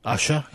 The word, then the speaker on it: Usher: Usher.